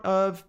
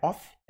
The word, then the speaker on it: of: Cuidado, pronúncia não é off, é of. Of.